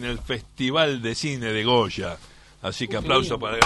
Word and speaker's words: Goya: Goya. – Goya. – Así pronuncia correcto. Goya.